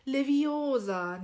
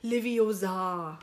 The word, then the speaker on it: Leviosa: Es heißt Wingardium Leviosa. Also das Gar ja. Oh. – schön lang und die Betonung auf dem O. Und jeder kennt doch aus dem Film das es heißt Leviosa, nicht Leviosa.